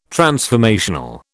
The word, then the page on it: transformational: British English